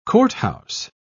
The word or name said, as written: courthouse